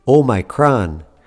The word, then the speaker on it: Omicron: By the way, did you hear how I pronounce the name of this new COVID-19 variant, Omicron? You might also hear it pronounced Omicron. If you go back to the Greek origin of the word, the textbook way to pronounce it is Omicron.